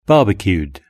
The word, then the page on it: barbecued: British English